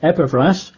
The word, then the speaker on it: Epaphras: As you also learned from Epaphras, I used to call him Epaphras, but I checked out the Greek, it should be pronounced Epaphras,